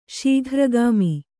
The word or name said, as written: ಶೀಘ್ರಗಾಮಿ